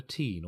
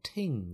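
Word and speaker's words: Teign: It's in the valley of the River Teen, or Ting, some people pronounce it.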